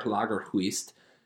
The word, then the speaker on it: Lagerkvist: I had to consult I think she was Swedish herself or perhaps Norwegian, but she, the old librarian in Juneau helped me with the pronunciation of Per Lagerquist. Kathy hates when I say it like that, but Per, P-A-R, Lagerquist, L-A-G-E-R-K-V-I-S-T, won a Nobel Prize in 1951 for fiction writing.